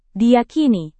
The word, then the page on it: diyakini: Indonesian